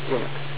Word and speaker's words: Linux: Hello, this is Linus Tormos, and I pronounce Linux as Linux.